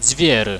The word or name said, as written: dzviery